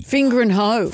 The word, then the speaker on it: Fingringhoe: F-I-N-G-I-R. – Start again. – F-I-N-G-R-I-N-G-H-O-E. – Fingering Hoe.